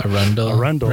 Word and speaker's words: Arundel: Like I was mentioning that Wiggins, the undercover who busts Orlando, taught me how to say the county south of Baltimore. – Arundel, right? Which I thought was Arundel.